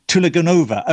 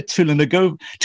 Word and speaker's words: Tulaganova: I do hope I've said that right. – Not right. Tulaganova. – Okay, say it again. – Don't even try. Shahida Tulaganova. – Tulaganova. Okay, so I stress the over.